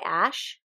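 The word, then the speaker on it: æ: Oh, I literally spelled Ash, which is not correct. A-Ash... Okay, so the A-E symbol thing is pronounced ash.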